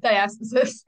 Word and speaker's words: diastasis: Yeah, diastasis.